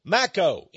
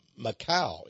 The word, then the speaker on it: Makow: Or send me the Illuminati book by Mako, M-A-K-O-W. I once pronounced that Macau, M-A-K-O-W. But Dr. Mako, he corrected me on that very nicely.